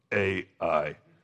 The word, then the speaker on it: Ai: Then they get to this city called Ai, or I, however you want to pronounce it. It's spelled A-I, so I pronounce it A-I.